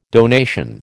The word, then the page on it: donation: American English